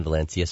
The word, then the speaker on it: Valencia: Yes, we have a campus in Valencia, Spain.